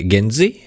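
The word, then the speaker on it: GenZ: Vai genzi? kā, kā būs pareizi? – Kā pareizāk būtu izrunāt? – Pareizi būtu genzi. – Jā. Jā. Ja kāds grib pierakstīt, tad ir gen un Lilijas z. Tas eh, nu, tā kā paudze, te eh, Z paudze.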